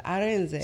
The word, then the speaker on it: Arinze: My last name is Arinze, but, you know, it's supposed to Arinze.